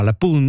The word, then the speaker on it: La Punt: Et moi, j'ai appris comme parole, je savais pas qu'on dit, on dit pas le pont, on dit la, donc c'est féminin, pun, alors qu'il y a un T à la fin, mais on le prononce pas, la pun. – Exact, la punt.